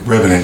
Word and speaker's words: Revenant: And it was like, I forgot the guy's name, but he was from, Reverend, or how you say it? – Revenant.